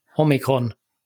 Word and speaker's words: omikron: Omikron. – Och så ska, du, man ska, ha betoning på det första om, o ja, och jag vet omikron.